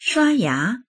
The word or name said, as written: shua1ya2